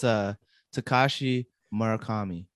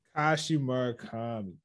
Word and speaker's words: Takashi Murakami: It's Takashi Murakami. – Takashi Murakami.